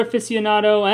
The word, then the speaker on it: aficionado: If you're into more than just X-Men comics, we have content for the video game lover, the sports fan, the beer aficionado, and much more. – Is it pronounced aficionado? I thought it was aficionado.